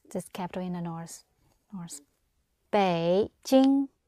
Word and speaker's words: Beijing: Now, the word for Beijing is pronounced Beijing. Bei. Red V for victory. Beijing. Jing. Green sound mouth.